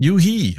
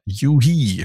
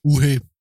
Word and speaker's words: u-he: Yuhi habe ich gelernt, heißt Urs Heckmanns Firma. Yuhi spricht man es auf Englisch aus. Was würden die Deutschen sagen? – Uhe.